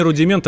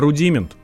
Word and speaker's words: рудимент: Я бы даже сказал не рудимент, а рудимент. Ну, Рудимин, раз, да.